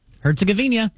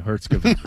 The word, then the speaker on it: Herzegovina: They play next Wednesday when they play Bosnia and Herzegovina. – Thank you. Herzegovina. We'll talk about that game coming up on Wednesday. – I feel like you're adding a syllable in there.